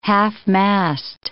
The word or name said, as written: half-mast